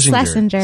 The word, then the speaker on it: Schlesinger: I'm always afraid to say his name. – Chris Schlesinger. – Thank you so much.